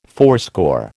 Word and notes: fourscore: American English

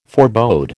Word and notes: forebode: American English

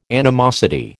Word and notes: animosity: American English

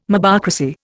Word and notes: mobocracy: Indian

